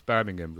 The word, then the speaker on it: Birmingham: The way you pronounce Birmingham was spot on, by the way. I was expecting a Birmingham, but I was, I don't know. That was pretty spot on.